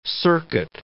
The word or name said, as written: circuit